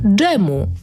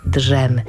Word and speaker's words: dżem: I drugi wyraz dżemu. Na przykład na śniadanie zabrakło dżemu. Przy okazji zauważcie, że wymawiamy dżem, a nie drzem. Nie mówimy drzem, chyba że drzemka.